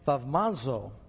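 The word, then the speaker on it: thaumazo (θαυμάζω): That word wonder there in Greek, I actually called Pastor Contis, a Greek pastor, last night, and he said it's pronounced thaumazo, and the Greek word means to wonder, to admire, to marvel.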